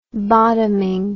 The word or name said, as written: bottoming